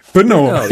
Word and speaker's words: Brno: And can you help me to pronounce this one name in the Czech Republic? Brno? How's it pronounced? – Brno. it's BRNO. It's actually just a 4-letter city.